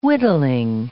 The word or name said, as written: whittling